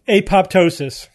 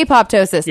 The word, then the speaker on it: apoptosis: Apoptosis. – Yeah. You say apoptosis.